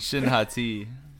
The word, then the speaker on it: Shin Hati: Shin Hati.